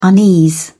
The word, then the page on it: unease: British English